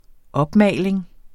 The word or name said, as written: opmaling